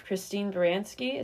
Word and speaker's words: Christine Baranski: And then we have Christine Baranski.